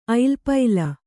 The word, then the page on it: ಐಲಪೈಲ: ailapaila